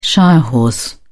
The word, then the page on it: shire horse: British English